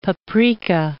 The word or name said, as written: paprika